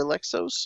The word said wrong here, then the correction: Alexios: Alexios. – Alexios, October 5th.